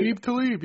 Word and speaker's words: Aqib Talib: How do you say it? Aqib Talib, right? – Aqib Talib, yep. – It's a spelling. There's no U in that spelling.